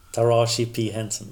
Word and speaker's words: Taraji P. Henson: Och den som är nominerad här är också namn som jag kanske uttalar fel här, men Tar Taraji P. Henson. – Taraji P. Henson.